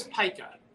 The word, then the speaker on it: Pyka: Well, my name is Pika, but P-Y-K-A, a lot of people throughout my life have mispronounced it Pika.